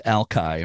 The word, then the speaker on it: Alki: I know that Alki Point itself, and I want to mention that for years I was never sure how to pronounce it, and it is pronounced Alki, right? Spelled A-L-K-I.